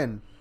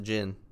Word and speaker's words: Djinn: I think it's the Djinn. Yeah. I haven't watched that movie in a long fucking time. – Djinn. I think it's called the Djinn. – Yeah. – I think the D is silent.